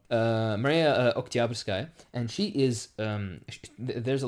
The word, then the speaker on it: Oktyabrskaya: I know that in Russian it's supposed to be emphasis on the middle syllable, so it's Oktyabrskaya.